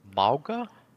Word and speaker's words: Mauga: His name is Mauga? – Yeah, I think that's how you say it. – I believe that's how we're saying it.